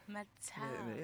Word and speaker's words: Metallica: Metallica.